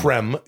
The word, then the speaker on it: Creme: It's spelled L-O-L. Cream in the old, you know, like the creme. C-R-E-M-E. Lowell Cream and Kevin Godley of the rock duo Godley and Cream. – Yeah. – Or Creme.